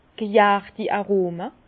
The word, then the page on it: g' järti Aroma: Bas-Rhin; Herrlisheim